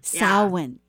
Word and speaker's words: Samhain: Samhain, yes.